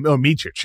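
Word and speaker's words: Mičić: Micić, yes.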